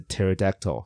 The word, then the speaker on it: pterodactyl: 我也不知道，只要问就是英文老师，我不知道说有些 P，像你知道什么有还有另外一个字的英文 P 是不发音的。好，你一定不知道，我跟你直接讲，就是呃，pterodactyl 就是翼手龙，翼手龙的英文是 pterodactyl，但是它的第一个字母不是 T 是 P，很多吧？